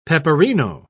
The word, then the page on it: peperino: British English